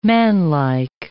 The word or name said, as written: manlike